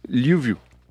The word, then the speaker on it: Lviv: Mais comment ça se prononce du coup? Vous avez trouvé l'information? – Lviv. – Très bien. – L-V-I-V.